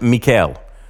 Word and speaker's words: Michal: We covered this in more detail, of David, where his wife, they pronounce it Michal, the youngest daughter of King Saul.